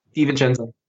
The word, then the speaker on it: DiVincenzo: That particular drop that he did to DiVincenzo. How do you pronounce his name? – DiVincenzo.